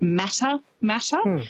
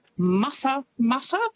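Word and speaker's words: Matamata: Quite often, because of how it's spelt, people will pronounce it Mata Mata, but it's Mata Mata, which is, I think, a lovely way to say it.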